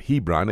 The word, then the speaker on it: Hebron: Now, you may pronounce it Hebron, H-E-B-R-O-N. The B has a V sound in Hebrew.